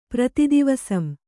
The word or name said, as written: ಪ್ರತಿದಿವಸಂ